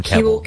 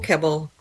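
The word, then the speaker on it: Keble: I've decided to alternate my pronunciation every other time between Keeble and Kebble. – Keeble.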